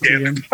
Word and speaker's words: Keoghan: Yeah, it's Keoghan.